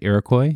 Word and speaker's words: Iroquois: Well, this episode is about the Iroquois Theater fire of 1903. Did I say that right, Iroquois? – Yes, you did.